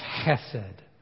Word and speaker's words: chesed: The Hebrew word behind mercy here is pronounced chesed. Chesed. It's a beautiful word if you can say it correctly.